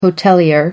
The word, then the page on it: hotelier: American English